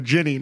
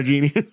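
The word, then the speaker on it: jinni: Ginny Winnie. Yeah, that's another thing. It's actually a ginny, not a genie.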